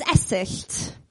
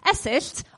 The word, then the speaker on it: Esyllt: My first name is Esyllt. Oh, yes. Oh, I've never had that response before. Esyllt, obviously Welsh. But surname, Sears, English.